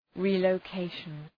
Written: relocation

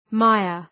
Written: mire

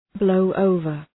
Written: blow over

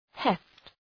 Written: heft

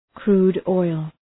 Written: crude oil

